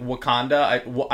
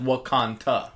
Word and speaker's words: Wakanda: Like Wakanda, I heard it as Wakanta.